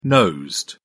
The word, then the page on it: nosed: British English